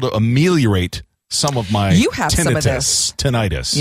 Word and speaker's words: tinnitus: And you'd even look up the definition and the accent is on first syllable, tinnitus.